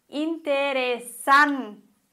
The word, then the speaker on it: interesante: Weiteres Beispiel. Interesante. Betonung auf der vorletzten Silbe. San. Und kein Akzent.